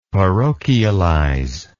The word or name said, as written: parochialize